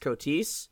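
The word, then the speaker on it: Koteas: Starring James Spader, Holly Hunter, and I'm not sure how to pronounce his name, but so Elias Cotis. – Yeah, okay, it's Cotis.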